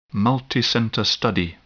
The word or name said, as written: multicenter study